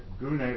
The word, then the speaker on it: gune: Gune, that's the English transliteration, that's the best English can do to get close to the Greek, the G-U-N-E with the accent on the E. It's pronounced Gune is the best I could get, the phonetic pronunciation.